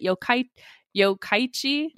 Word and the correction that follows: Yokkaichi: because I am really bad with Japanese pronunciation, but I did the how do you pronounce video. – Yeah. – It's pronounced Yokkaichi.